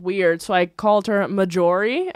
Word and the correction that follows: Marjorie: and she yelled at me, and she was like, it's Marjorie, you can't read.